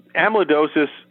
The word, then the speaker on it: amyloidosis: Back in, was it 2017, the diagnosis for, and make sure I'm saying it right, amyloidosis? – Amyloidosis, yeah. Mm-hmm.